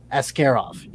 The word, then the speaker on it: Askarov: Whereas in a lot of Russian names, the hard syllable is that second one. So you get like Askarov instead of Askarov.